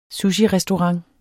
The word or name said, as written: sushirestaurant